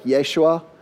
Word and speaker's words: Yeshua: It's actually in Hebrew, it'd be pronounced more like Yeshua.